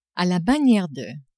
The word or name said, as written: a la maniere de